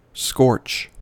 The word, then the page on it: scorch: American English